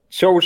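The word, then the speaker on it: Zhou: Äh, Joe spricht man das, glaube ich, aus. – Wurde uns ah, mal bestätigt.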